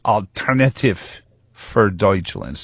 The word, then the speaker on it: Alternative für Deutschland: But I want to get back into the story of the Alternative für Deutschland. I'm told it's Alternative für Deutschland, spelled like, and I imagine it's one of those words that came along after the Dutch, the Germanic languages had all metastasized.